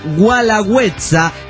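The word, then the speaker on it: Guelaguetza: Sí, sobre, del cerro en Oaxaca, el Gualagüenza. sino Gualagüetza, como se dijo en el programa pasado.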